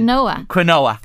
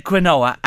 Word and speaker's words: quinoa: Quinoa. – Yeah. – You used to say? – Yeah, I said... – Quinoa. – Yeah, yeah. – I said quinoa and it's quinoa.